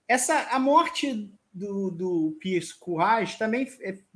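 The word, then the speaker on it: Piers Courage: Antes de, de tê-lo como piloto, que, segundo me disseram, uma, a pronúncia em inglês é Pierce Courage.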